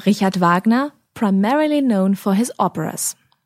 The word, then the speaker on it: Wagner: Let's just run through the correct German pronunciation of those composers' names again. Johann Sebastian Bach, Georg Friedrich Händel, Richard Wagner, Robert Schumann, Joseph Haydn and Wolfgang Amadeus Mozart.